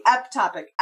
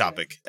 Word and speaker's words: ectopic: That's basically an atopic pregnancy. Ectopic. I don't know what the correct pronunciation is. – Ectopic. Ectopic. – Right. – Okay, because in EMT school, they told me atopic, but it's where it grows in the fallopian tube.